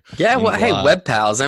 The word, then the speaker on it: WebPals: Cody is partnership manager at WebPals Group. Is that how you say it? – That is right. Yes.